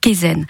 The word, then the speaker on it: Kaizen: Tout à fait, c'est la méthode Kaizen.